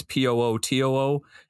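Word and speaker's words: Potoo: I think it's the Putu. I don't know how to say this. It's Pootoo.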